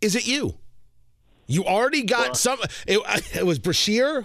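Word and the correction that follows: Brecheen: who put you up. I'm pronouncing his name wrong. Josh. Oh, I can't remember his last name. – Josh Brekeen, a new member from Oklahoma.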